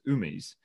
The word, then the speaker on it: Amis: While they were generally called Amis or pronounced Umis, the locals called them Zufa or Zupfa.